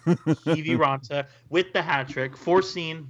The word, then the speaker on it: Kiviranta: There were goals 10 seconds apart in the third, capped off by Kivaranta hat trick foreseen by Hockey Jerk. – Kiviranta, please get our Lord and Savior's name correctly.